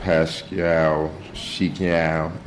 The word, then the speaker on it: Pascal Siakam: Rolling with Pascal Siakam. Yeah, you got to say it like that, the way he, man, put a little twist on it.